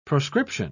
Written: proscription

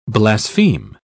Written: blaspheme

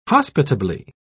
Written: hospitably

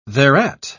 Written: thereat

